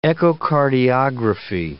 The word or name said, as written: echocardiography